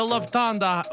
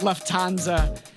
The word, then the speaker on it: Lufthansa: The Lufthansa Lufthansa heist is the biggest heist this in is U.S. The 821 news. We apologize for our earlier misstep. Uh, it is pronounced Lufthansa and uh, we apologize.